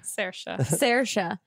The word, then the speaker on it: Saoirse: How do I say her name? – Saoirse.